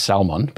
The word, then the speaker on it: Salmon: Salmon, who I think is supposed to be pronounced Salmon, but we were Asa, Zerubbabel, for example.